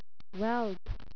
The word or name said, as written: whelp